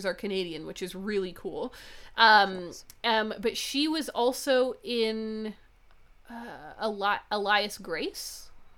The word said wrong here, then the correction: Alias Grace: Is that how you pronounce it? Alias Grace? – Alias Grace? – Yeah.